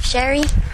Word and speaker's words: Sherry: Sherry.